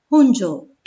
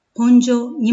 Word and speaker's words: 本庄: 以前にも本庄駅の自動音声のアナウンスが本庄と発音されていることに対し、たくさんのご意見が寄せられました。市としても JR に申し入れ、私たちが耳慣れた本庄に戻してもらいました。